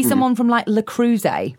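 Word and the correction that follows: Le Creuset: How did you pronounce that? – Le Cruze. – How do you pronounce it? – I think it's La Crusette. – Babe, it ain't. – It may not be, but it's definitely not Le Cruze.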